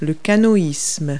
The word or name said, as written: canoeing